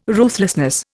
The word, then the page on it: ruthlessness: Indian